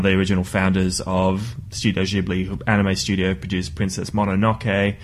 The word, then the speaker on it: Ghibli: Ghibli? Ghibli? – It's, oh, gosh. I've heard it both ways, and the Italian pronunciation, I think, is Ghibli, but they all say Ghibli, which is wrong.